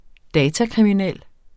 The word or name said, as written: datakriminel